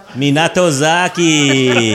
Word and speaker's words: Minatozaki: Minatozaki.